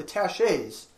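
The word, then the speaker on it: taches: Exodus chapter 26, verse 33 says, and thou shalt hang up the veil under the tachets. I think I said that right. I don't know interior decoration very well. I believe that word is tachet. That's how the French would pronounce it.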